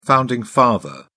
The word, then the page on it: founding father: British English